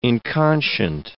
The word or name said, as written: inconscient